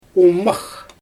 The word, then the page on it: umax: Xyzyl